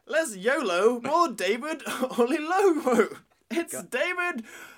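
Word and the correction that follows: Oyelowo: So I mangled his pronunciation. – No, that was coming out right. – But I say less YOLO, more David O'Yellowo. It's David O'Yellowo.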